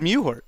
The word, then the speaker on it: Mewhort: Meward? – I think it's Mewhort. – No. That's in Mewhort. – No. Mewhort. The W is basically silent.